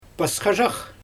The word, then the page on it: pasxazhax: Xyzyl